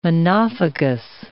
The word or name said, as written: monophagous